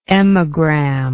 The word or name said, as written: emagram